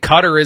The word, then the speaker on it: Qatar: I mean, I've heard it pronounced Gutter, too. – Yeah. I think Qatar is the way that they claim we're supposed to.